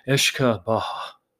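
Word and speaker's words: uisce beatha: And let me see if I can pronounce it. Ishka Baha. Ishka Baha. So supposedly that is the pronunciation, the Gaelic pronunciation of whiskey, meaning water of life.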